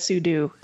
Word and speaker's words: sudo: But the co-inventor, Robert Cogshall, says it's meant for super user do. You should say sudo. So in this case, Axon ruled sudo. Sudo is the proper pronunciation. We've just all been saying it wrong.